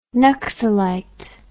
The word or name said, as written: naxalite